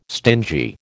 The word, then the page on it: stingy: American English